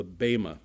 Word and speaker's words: Bema: This is where we come to the reward bench, or it's called in the in the scriptures called the Bema, B E M A, long E. B E M A, Bema.